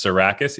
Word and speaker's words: Tsourakis: It's Tsourakis.